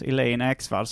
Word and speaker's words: Elaine Eksvärd: Men han känns, ja, det känns ju mer ondskefullt också om Elaine Eksvärd, eller hon ska uttalas, Elaine Eksvärd, som kritiserade oss för att vi skämtade om pedofili efter att äta bajstår.